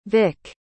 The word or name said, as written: vic